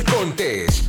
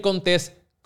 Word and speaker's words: Contés: Y con ustedes, mi Miguel Contés, con acento en la E. Bienvenidos a otro episodio de Cereal Empresarial. Mi nombre es Miguel Contés con acento en la E y aquí estamos haciendo este podcast para que tú puedas desarrollar tu negocio.